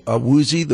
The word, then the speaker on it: Awuzie: Yeah, one of those cornerbacks everybody's talking about is, if I'm pronouncing this right, Shadobi Awuzie, the cornerback from Colorado.